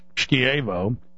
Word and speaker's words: Schiavo: I've been focused on the Terry Schiavo, properly pronounced Schiavo, Schindler case.